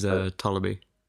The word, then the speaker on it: Ptolemy: His name is Ptolemy.